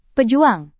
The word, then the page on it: pejuang: Indonesian